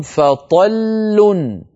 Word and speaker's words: فطل: في الآية 65 فإن لم يصبها وابل فطل، هذه الكلمة آخرها لامان، فطل لام ساكنة تتلوها لام مضمومة، جربوا فطل.